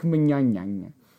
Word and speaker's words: Mignogna: Vic Mignogna.